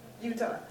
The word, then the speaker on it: Eutaw: And our work was centered in Utah. The county seat is spelled E-U-T-A-W. Utah.